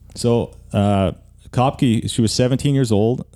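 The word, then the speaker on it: Koepcke: I'm not sure how to pronounce her name. It's pronounced Kopke, who was 17 years old at the time, fell thousands of feet, still strapped into her seat.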